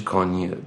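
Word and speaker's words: Kanye: مثلا کانیه وست، حالا تلفظ درستش کانیه، درستش کانیه وست،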